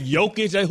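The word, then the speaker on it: Jokić: Jokic. Who has a silent J? That ain't American.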